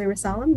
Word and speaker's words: Ressalam: So my name's Julie Ressalam.